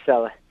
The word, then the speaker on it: Celle: called Celle. And pronounced Sella.